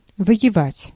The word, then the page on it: воевать: /vəjɪˈvatʲ/